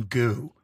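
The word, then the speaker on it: Gü: some goo. It's spelled G-U.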